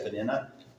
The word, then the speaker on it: Peninnah: I think it's supposed to be pronounced Penina, but we all say Peninnah.